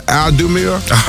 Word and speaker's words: Audemars: Audemars.